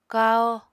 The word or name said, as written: kahó